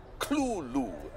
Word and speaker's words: Cthulhu: Well, in a letter of about 1934, Lovecraft actually has a phonetic pronunciation, and he says it's two syllables, Cthulhu.